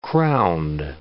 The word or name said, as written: crowned